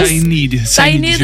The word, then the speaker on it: Sinead: Ce soir, on reçoit Sinead, je vais après demander comment ça se prononce. – Sinead, j'ai la confirmation. – Faut l'accent.